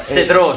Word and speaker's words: Cedraux: Poi abbiamo ascoltato invece, cari amici, Oggi è Weekend di Cedraux. – Cedro si pronuncia, è francese.